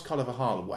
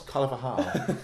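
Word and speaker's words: Carvalhal: By the way, Carlos Carvajal, how do you pronounce his name? – You just nailed that, by the way. – Did I? – I think so, yeah. – Superb. Carlos Carvajal.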